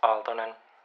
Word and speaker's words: Aaltonen: My name is Simo Sakari Aaltonen. Finnish doesn't aspirate. It's not T. For example, Aaltonen, it's Aaltonen. We say it softer.